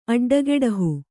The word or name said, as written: ಅಡ್ಡಗೆಡಹು